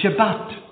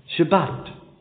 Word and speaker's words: Shabbat: The Jews pronounce it, and this is the Hebrew pronunciation of it, they pronounce it Shabbat. Shabbat.